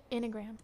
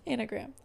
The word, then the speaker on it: Enneagram: Enneagram. – Enneagram.